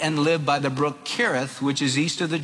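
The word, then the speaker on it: Cherith: Kereth, which is east of the Jordan." Now notice in the Old Testament, anytime you read a CH, it's pronounced with a K. The brook Kereth, which is east of the Jordan.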